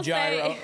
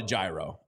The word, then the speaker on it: gyro: But I get a gyro. I've always called it a gyro. A gyro, I believe, is what the proper pronunciation is of, if we're in Greek or in Greece, I think I don't know on that one.